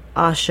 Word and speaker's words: Axe: It's spelled A-X-E, but you're supposed to pronounce it Ashe.